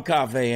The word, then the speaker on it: Coffey: And Cafe is spelled like coffee with an E-Y. – E-Y. – It's like the drink, only spelled different. C-O-F mm-hmm.